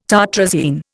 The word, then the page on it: tartrazine: Indian